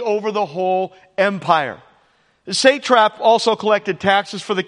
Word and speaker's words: satrap: He sets up 120 satraps to be over the whole kingdom. What are satraps? And by the way, I looked up how to say it. I'm saying it correctly, just in case. Satrap.